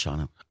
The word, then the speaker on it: Shawano: Well, I always say Shawano. – It's Shawano.